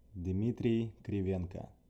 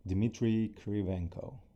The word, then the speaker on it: Dmitrii Krivenko: Yeah, my name is Dmitrii Krivenko, if I say it in a Russian way. So for Americans, it would sound like Dmitrii Krivenko.